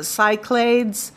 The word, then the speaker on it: Cyclades: Well, the proper saying is Cyclades. – Okay. – In the Greek pronunciation. Otherwise, it's called the Cyclades or the Cyclades.